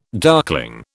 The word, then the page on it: darkling: British English